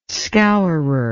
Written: scourer

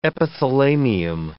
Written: epithalamium

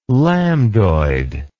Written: lambdoid

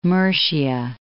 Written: Mercia

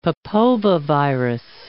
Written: papovavirus

